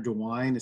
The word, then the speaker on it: DeWine: The other one that I've heard rave reviews from my sister, who actually lives in Ohio, is Governor DeWine. Is that his name, Nick? Am I saying that right? – Yeah, that's the one.